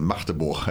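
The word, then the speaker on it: Magdeburg: Ähm, ja, war auf jeden Fall ganz interessant und da wurden wir auch darüber aufgeklärt, dass es Machteburg heißt. Machteburg. Ich sage Hamburg, sagen wir ja auch schon, da ist das G auch weg, aber Machteburg, ja, jetzt habt ihr was gelernt. So spricht man das richtig aus.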